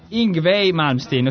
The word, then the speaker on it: Yngwie Malmsteen: Sì, del guitar hero Yngwie Malmsteen. Ho scoperto che si legge così, eh.